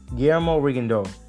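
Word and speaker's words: Rigondeaux: He fought better than Jason Sosa, and he fought better than Guillermo Rigondo. Yes, that's how you pronounce his name.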